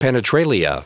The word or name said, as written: penetralia